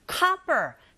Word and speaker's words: copper: 이런 경우에도 드 사운드는 R 사운드에 가깝게 발음이 들리게 되고 그렇게 하시면 되고 copper, 자, O이지만 O 자체의 철자에 강세가 가게 되면 O가 아닌 아 소리로 발음하게 되죠.